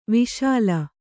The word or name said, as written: ವಶೀಲಿ